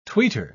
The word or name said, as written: tweeter